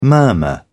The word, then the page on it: murmur: British English